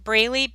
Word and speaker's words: Braley: My name is spelled JoLynn Braley, BRALEY.